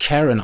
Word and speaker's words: Karen: Karen, die kommt eigentlich aus dem englischen Sprachgebrauch und wird Karen ausgesprochen dort.